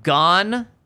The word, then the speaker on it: Gonzaga: Also, thanks for pronouncing Gonzaga and Spokane correctly.